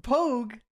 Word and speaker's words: Pogue: So, okay, P-O-G-U-E. That's Pogue. That'd be Pogue.